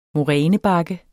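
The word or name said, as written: morænebakke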